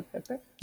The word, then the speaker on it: Pepe: How do you say Pepe? Mm-hmm. Yeah, the Pepe. – You got it. Pepe's perfect. – Uh-huh.